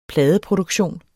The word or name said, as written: pladeproduktion